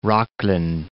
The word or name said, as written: Rocklin